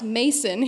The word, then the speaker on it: Macon: I recorded the audiobook, and the people at Penguin Random House wrote back to me on a couple things and said, "Hey, you mispronounced Macon." it's supposed to sound like bacon. And I said, "Oh no, let me introduce you to a few country songs off the internet. It's pronounced Mason here."